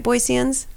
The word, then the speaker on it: Boiseans: Did I say that right? Boiseans? – Boiseans. Okay. So, as far as I know. Okay. As long as I say Boiseans. – Yeah, there's no Z in there.